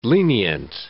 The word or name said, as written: lenient